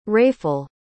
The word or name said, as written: wrayful